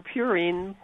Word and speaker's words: purine: I can't help you much with your purine, which is the correct pronunciation, by yes.